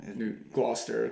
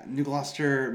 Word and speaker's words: New Gloucester: Yeah, we're, we're in the basement in New Gloucester, did I say wait? Did I say that wrong? New Gloucester, close enough. Yeah, New Gloucester, Maine.